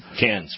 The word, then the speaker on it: Cannes: I don't say it right. It's Cannes, right? – Yes, Cannes.